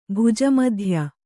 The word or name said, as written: ಭುಜಮಧ್ಯ